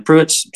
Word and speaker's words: Prewitt: And Prewitt's spelled a little odd for some people.